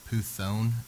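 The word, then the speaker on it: Puthon (πύθων): And it's pronounced Puthon in the Greek, okay? It's where we get the word python from.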